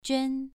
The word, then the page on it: juan: 2